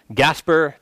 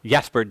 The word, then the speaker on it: Gaspar: But there was a certain Protestant man, Gaspar de Coligny, or Jasper de Coligny, depending on if you want to pronounce the G or not.